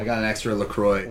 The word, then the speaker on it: LaCroix: It's pronounced LaCroix. – Talking about nostalgia. – LaCroix, yes, excellent.